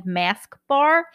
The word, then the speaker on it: Masque: So it's called Shield and Soothe, and it's by the brand Mask Bar. So Mask is spelled M-A-S-Q-U-E.